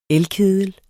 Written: elkedel

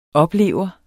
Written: oplever

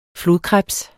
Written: flodkrebs

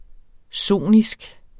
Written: sonisk

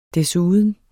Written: desuden

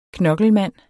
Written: knokkelmand

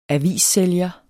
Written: avissælger

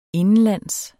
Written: indenlands

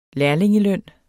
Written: -ling

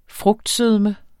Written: frugtsødme

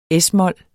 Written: es-mol

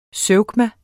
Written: zeugma

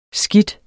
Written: skidt

